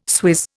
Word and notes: swizz: Indian

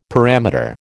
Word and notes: parameter: American English

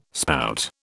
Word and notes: spout: British English

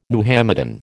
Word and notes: Muhammadan: American English

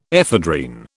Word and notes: ephedrine: British English

